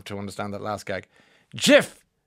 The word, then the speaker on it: GIF: Okay, so this man, Johnny Smartass over here, this man has said that it, it, those using the hard G are wrong. End of story. So it's a GIF. It's a soft G. GIF, GIF, GIF.